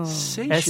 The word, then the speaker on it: Seychelles: Oh, the Seychelles. – Whoa. Seychelles. – S-E-Y-C-H-E-L-L-E-S, right? – Right.